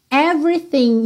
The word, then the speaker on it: everything: E nessa palavra everything a gente tem duas letras que não são pronunciadas, ok? O segundo é, que a gente não fala every, a gente fala every, every. E o G final do thing.